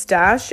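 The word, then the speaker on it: Stache: I think it's pronounced Stash. It's S-T-A-C-H-E.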